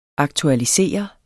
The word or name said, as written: aktualisere